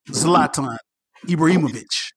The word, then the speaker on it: Zlatan Ibrahimovic: and that's Zlatan Ibrahimovic. I hope I said that right. – You said it right.